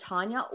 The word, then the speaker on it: Taunia: Again, you can find me on Facebook at Diva Tanya or Diva Tanya Music. And my name is spelled T-A-U-N-I-A.